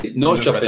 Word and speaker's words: Norrköping: What was it like playing for IFK Norrköping? – Nearly. Norrköping. – That as it sounded. Oh, there you go.